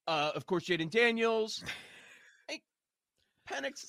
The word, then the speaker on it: Penix: Yeah, because he said Phoenix right away, and then Mm-hmm. specifically intentionally said Penix like seven times after that. Like, so he obviously knows how to say it correctly, which makes me think he's messing with us the first time.